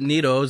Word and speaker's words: Neto: It'd be real neato if you pronounce it neato.